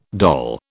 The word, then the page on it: doll: British English